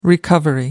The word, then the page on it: recovery: American English